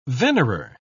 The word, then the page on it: venerer: British English